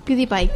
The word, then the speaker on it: PewDiePie: YouTube PewDiePie, ¿cómo se dice? – PewDiePie.